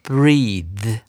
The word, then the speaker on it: breathe: 那它的发音呢不太一样，它要念成 breathe 啊。注意到这个 e a 发的是 e 长母音，然后呢 t h 后面加了 e 之后，它要把舌头伸出来念成 z 啊，它是有声子音。